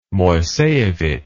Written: moiseivich

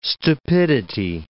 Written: stupidity

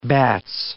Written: bats

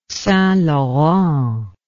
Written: St Laurent

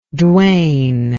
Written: dwayne

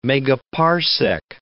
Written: megaparsec

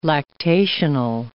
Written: lactational

